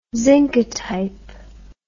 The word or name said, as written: zincotype